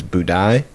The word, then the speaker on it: Budai: So that was this other guy who is a 10th century Chinese monk named, I believe the pronunciation is Budai.